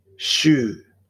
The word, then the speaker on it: syv: Do remember that it is common in Norway to pronounce the number 7, that we pronounce it syv, and it is also common to pronounce 7 by saying sju, sju, sju.